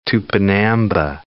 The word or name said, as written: Tupinamba